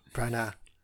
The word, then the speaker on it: Branagh: You don't pronounce the G in Branagh. – Branagh.